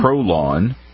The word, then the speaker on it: Prolon: Dr. Longo's Prolon? – Prolon. Each month. – It rhymes with colon.